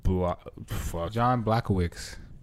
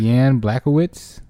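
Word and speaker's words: Błachowicz: John Blachowicz. – That's not how you say it. – 26-8. Yan. It's probably Yan. – Yan Blachowicz.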